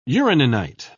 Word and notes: uraninite: British English

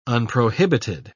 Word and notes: unprohibited: British English

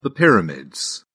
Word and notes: pyramids: British English

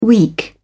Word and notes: weak: British English

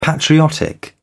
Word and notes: patriotic: British English

